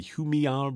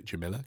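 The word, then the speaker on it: Jumilla: I learnt that's how you pronounce it from the free copy of Glug magazine they bundle inside. Otherwise, I would have called it Jamila, because that is how it is spelt.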